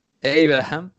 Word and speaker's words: Abraham: Y luego está por ahí Tammy Abraham, que tú me dirás cómo se pronuncia, no se pronuncia así. – Abraham.